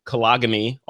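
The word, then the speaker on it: Köln: in Kolagany, also known as Cologne, if you want to pronounce it that way, Germany.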